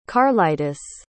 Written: karlitis

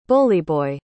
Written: bullyboy

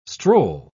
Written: stroll

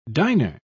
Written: diner

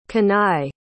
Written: Kanaye

